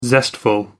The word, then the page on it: zestful: American English